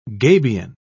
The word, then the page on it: gabion: British English